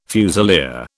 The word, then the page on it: fusilier: British English